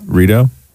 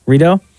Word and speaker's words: Rideau: Rideau? – Rideau. – Rideau.